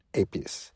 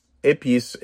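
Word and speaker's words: épis: So the first thing you said is you marinate chicken thighs in épices. So tell us about épices, and it's spelled E-P-I-S.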